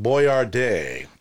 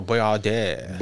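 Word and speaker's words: Boiardi: His name was actually pronounced Boyardee. – Oh, Boyardee.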